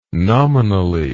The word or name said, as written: nominally